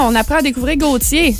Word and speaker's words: Gotye: G-O-T-Y-E. Mm-hmm. Comme ça se prononce. – Comme ça se prononce. – Oui, moi je disais Gauthier avant, parce que c'est assez spécial que ça se prononce Gauthier.